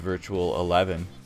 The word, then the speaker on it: Virtual XI: In March of 1998, the band's second and last album with Blaze, Virtual XI, which kept the Roman numeral theme going, but it's pronounced Virtual 11, apparently.